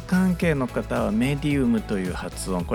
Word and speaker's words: medium: 霊媒を意味する英語をメディウムというふうに発音していこうと思っています。